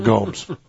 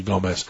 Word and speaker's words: Gomez: And you know this, Michael Goudeau, for years now, ever since my good friend Ron Gomez, who pronounces it wrong, Gomes, but it's actually Gomez, claims to be Portuguese.